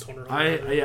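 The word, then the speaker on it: Hunter × Hunter: But then again, you didn't know it was called Hunter Hunter or pronounced Hunter Hunter.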